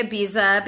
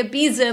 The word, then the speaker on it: Ibiza: You know, we say Ibiza because we're, you know... Americans in the movie and already like people on Instagram are like, it's Ibiza. – You're saying it wrong. I'm like, I know, but we say Ibiza in the US. – Yes, I'm sorry. – I'm so sorry. I know that it should be Ibiza.